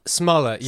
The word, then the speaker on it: Smollett: Is it Jussie Smollett? – Smollett, yeah. Smurfette. Yeah. I had to watch so many videos to get it right because I kept like making the T's silent and making it French.